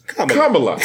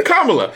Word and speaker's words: Kamala: Thinking like, oh wait, Kamala. Okay, Kamala.